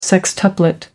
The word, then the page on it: sextuplet: American English